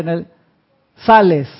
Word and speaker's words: Nelly Sales: Dice Nelly, se movió, pero estaba Nelly, que dice Nelly Sales, se lee como se escribe.